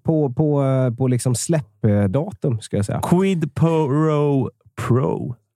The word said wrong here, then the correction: Quid Pro Quo: Quid Pro Quo. Inte helt enkelt att uttala, men Nej.